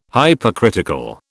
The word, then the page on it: Hypercritical: British English